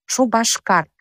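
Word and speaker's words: Шупашкар: Например, чувашское название города Чебоксары – «Шубашкар». Там произносится буква «ба», но на письме пишется буква «п».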